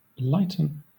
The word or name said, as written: lighten